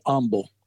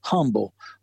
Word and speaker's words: Humble: But in my research, I kept reading about Michael Humble, and it's actually pronounced with the H being silent, Michael, or Humble, not, it's spelled H-U-M-B-L-E, and the H is silent. So you, when you read it, you're going to say Humble, but the family is always pronounces it without the H.